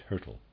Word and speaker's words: Hyrtl: Footnote, trying to pronounce the name Hertel, readers aside, spelled H-Y-R-T-L, and I'm pronouncing that Hertel, end of readers aside.